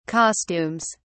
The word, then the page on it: costumes: British English